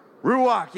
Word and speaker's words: ruach: Ruach, and you have to say it like that. Like you're going to hawk a loogie or something, you know, in the back of your throat.